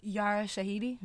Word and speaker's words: Yara Shahidi: Yara Shahidi.